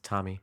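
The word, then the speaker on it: Tomi: Is it Tommy? Tommy? – It's Tommy. – Tamai? – But the point Tamika.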